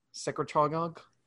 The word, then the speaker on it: secretagogue: So it's orally administered and it's a growth hormone secretagogue. Is that how you pronounce that? Secretagogue? – Secretagogue.